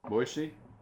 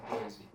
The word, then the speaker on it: Boise: Boise? – Uh-huh. Very good. Easterners would say Boise, so congratulations, you say it like I do.